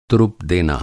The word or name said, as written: तुरुप